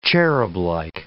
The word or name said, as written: cherublike